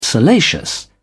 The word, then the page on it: salacious: British English